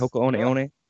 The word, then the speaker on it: Hoka One One: One thing that is cool about Scott is your whole running team, the Northern Arizona for Hoka One One, right? Am I saying that right? Yes. Hoka One One? – Nailed it.